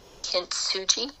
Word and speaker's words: Kintsugi: Kintsugi. K-I-N-T-S-U-J-I. Kintsugi.